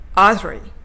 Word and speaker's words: artery: では、このアヒル口で、アードリー、えっ、ー、と、さっきのカタカナ、アードリーですね、をイメージして、アードリーと発音していきたいと思います。